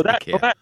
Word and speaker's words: IKEA: But is that actually how you pronounce it? Meant to pronounce IKEA. – Yeah. That's how they, in Sweden, it's IKEA.